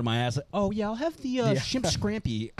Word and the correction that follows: scampi: You've had shrimp scampi?